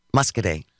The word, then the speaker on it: Muscoda: I mispronounced Muscadet. – See, I knew there was a Muscadet, and I guess I wasn't paying attention when you said Muscoda, but there's no Y on the end of it.